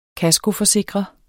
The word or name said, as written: kaskoforsikre